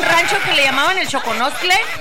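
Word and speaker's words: Xoconostle: Choconoxtle. – Sí. Se escribe con X, pero se pronuncia Xoconoxtle.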